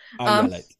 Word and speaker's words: Ayelet: First of all, my name is pronounced Ayelet, as in Ayelet. I yell it. – Ayelet, yeah.